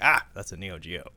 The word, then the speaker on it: Neo Geo: SNK made the Neo Geo machine, or I should say it correctly, Neo Geo.